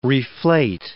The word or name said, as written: reflate